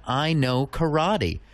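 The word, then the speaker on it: Inoke Errati: We're going to close out the show today with brand spanking new music from Windsor's own I Know Karate. And if you're looking them up online, you'll want to spell it I-N-O-K-E space E-R-R-A-T-I.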